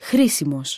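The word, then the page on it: χρήσιμος: Greek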